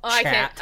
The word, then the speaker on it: chat: Well, chat. I can't even say. The H is silent.